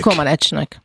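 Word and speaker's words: Comaneci: Egyáltalán, Zsuzsa, hogy ejtik a románok a nevét? Komanecsnek, vagy Komanecsinek? – Komanecsnek.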